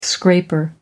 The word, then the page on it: scraper: American English